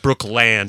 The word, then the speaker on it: Brookland: And it's actually pronounced Brookland here in D.C.